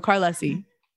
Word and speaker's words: Carlesi: So that's not how you pronounce my name. – Yeah, I was going to say it sounds much different than the way I had ever imagined to pronounce it, if that was real. Was that the way you do? – No, Carlesi.